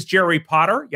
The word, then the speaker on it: Jerry Potter: My name is Jerry Potter. Yes, it rhymes with the boy wizard,